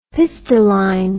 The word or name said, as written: pistilline